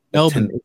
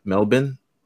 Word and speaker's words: Melbourne: Melbourne. It's Melbourne. – Melbourne? – Yes. – Dar is silent? – Yes. – You sure? – Yes, it's Melbourne.